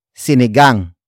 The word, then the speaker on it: sinigang: It is an object marker that introduces what the neighbor cooked, and that is sinigang.